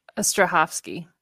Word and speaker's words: Strahovski: Strahovski.